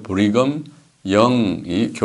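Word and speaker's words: Brigham Young: 자, 그를 이어 1847년 브리검 영이라고 발음하는데, 브리검 영이 교회를 미국의 유타주로 이주하여 몰몬교의 본거지로 삼았습니다.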